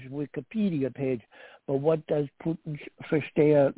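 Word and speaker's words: Putinversteher: There's a term in German which is pronounced Putin-Verstehe.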